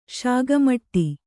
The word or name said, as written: ಶಾಗಮಟ್ಟಿ